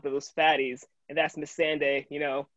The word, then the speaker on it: Missandei: Missandei.